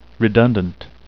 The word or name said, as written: redundant